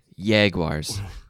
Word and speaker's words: Jaguars: Soft J, Jaguars.